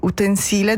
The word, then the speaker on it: utensile: Allora, se si usa come sostantivo, come in questo caso, l'accento va sulla I, utensile da cucina.